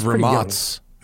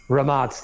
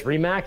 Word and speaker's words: Rimac: Familiar enough to know it's pronounced Ramatz. – Ramatz, that's it. He came on and I was like, how do you say this, Rimac?